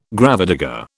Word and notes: gravedigger: British English

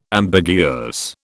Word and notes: ambiguous: British English

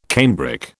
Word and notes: cambric: British English

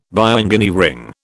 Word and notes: bioengineering: British English

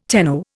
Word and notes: tenno: Indian

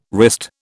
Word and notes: wrist: British English

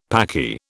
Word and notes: Paki: British English

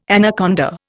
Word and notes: anaconda: Indian

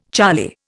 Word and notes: charlie: Indian

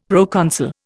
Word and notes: proconsul: Indian

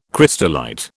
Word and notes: crystallite: British English

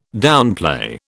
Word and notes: downplay: British English

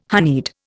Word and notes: honeyed: Indian

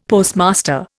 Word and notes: Postmaster: Indian

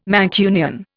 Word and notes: Mancunian: Indian

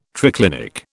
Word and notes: triclinic: British English

